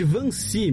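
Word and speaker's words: Wannsee: 20 de janeiro de 1942, há 75 anos, ocorria a Conferência de Van Ness. – E, não, na verdade é a conferência de Vancy, meu caro Matias. Ah. Seu alemão tá em. Você falou em holandês, é alemão.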